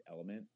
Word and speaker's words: LMNT: It's called LMNT. I'm guessing they pronounce it element.